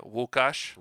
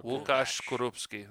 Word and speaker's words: Łukasz Skorupski: That's how you say it. Łukasz. – Okay. – Łukasz Skorupski. It's Polish.